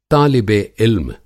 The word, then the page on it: तालिबे-इल्म: طالبِ علم